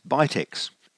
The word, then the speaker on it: BITX: Bill led off with the PowerPoint presentation establishing the correct pronunciation of BITX as Bitex, introducing the designer Ashar Farhan VU3ESE from far-off Hyderabad in India, and taking the group briefly through the history of the projects.